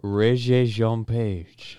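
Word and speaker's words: Regé-Jean Page: Régé Jean Page.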